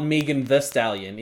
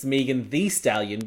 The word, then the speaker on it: Megan Thee Stallion: it's not Megan Thee Stallion. It's Megan Thee Stallion because it's spelled T-H-E-E.